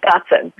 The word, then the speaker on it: Gotzen: I always like to say it's God, Son, but it's just like the feminine version, Gotzen.